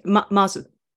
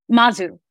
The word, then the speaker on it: Mazu: It's okay, Mazu. – Mazu.